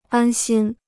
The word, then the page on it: 安心: ān xīn